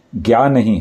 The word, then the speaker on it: ज्ञानी: इसका ये है ज्ञानी हम इसको ज बोलते हैं ज्ञानी ज्ञ ये ज्ञ है ज्ञा नहीं है और बोलना भी ज्ञानी ही चाहिए